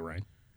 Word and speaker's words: Reyne: Rain Smith, I guess it's Rain, R-E-Y-N-E. – Spell it again. – R-E-Y-N-E. – Yeah, I'd say Rain.